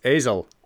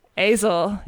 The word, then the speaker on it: Aizle: It's spelled A-I-Z-L-E. – Yeah, I think it's I think it's Azel. – Azel. Yeah. Azel.